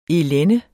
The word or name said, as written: elende